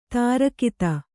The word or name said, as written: ದೀರ್ಘತೆ